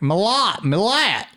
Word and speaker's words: Milat: We didn't know if it was Milat or Millet. – Yeah. – And I think it just depends on your accent. You'd be like, Millat. – Yeah, it's like somewhere between Millet and Millat, like between the E and the A, but there's not, that vowel sound doesn't really exist in our language.